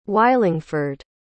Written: wylingford

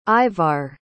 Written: Ivar

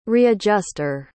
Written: readjuster

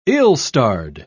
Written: ill-starred

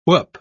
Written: whoop

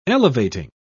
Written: elevating

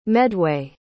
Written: medway

